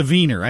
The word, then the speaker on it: Wiener: I know it's a wiener. I know it's pronounced with a V.